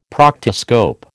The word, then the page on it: proctoscope: American English